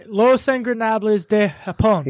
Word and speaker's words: Los Ingobernables de Japón: Los Ingobernables de Japón.